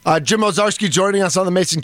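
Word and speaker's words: Owczarski: It's at Jim, ow, O-W. Then he's the czar, C-Z-A-R. And then you go skiing, Ozarski. It's Ozarski, but...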